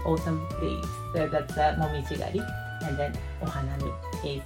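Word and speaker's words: koyo (紅葉): We have two pronunciation, koyo and then momiji. And then koyo means red leaves in autumn and the momiji means maple leaves.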